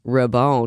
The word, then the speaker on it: Raybon: And actually, talking about name pronunciations, it's Raybon because it's French. Our family is from France. We have French ties.